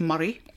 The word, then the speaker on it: Moray: Is it A, Angus, B, I better say this right, I would say Moray, but it's Murray.